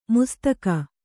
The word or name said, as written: ಮುಸ್ತಕ